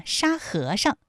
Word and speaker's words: 沙和尚: 沙和尚这三个字，他们的声调分别是一声、二声和轻声，特别注意沙和尚，哎，在这里面最后那个上它是读轻声。